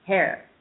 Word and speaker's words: Hare: It rhymes with tear. Hare.